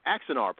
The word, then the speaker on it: Axanar: So we have this fan film for Star Trek that somehow raised the ire of CBS and Paramount Studios from the Xanar, Axanar, pardon me,